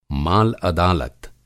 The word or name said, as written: माल-अदालत